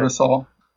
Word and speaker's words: Barbasol: He finished 11th at the Barbasol. Did I pronounce that right? – Barbasol. – There you go.